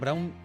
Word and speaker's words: Browne: Jackson Browne. Siempre me empeño en pronunciar la E final y es cierto que Jackson Browne siempre, siempre. No se ha pronunciado.